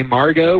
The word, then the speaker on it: Margot: My sister finally had the baby, Margo, with a silent T on the end. So M-A-R-G-O-T.